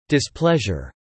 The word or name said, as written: displeasure